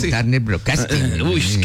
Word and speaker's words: Turner Broadcasting: En 1992, la empresa Turner Broadcasting, ¿lo dije bien? – Turner Broadcasting.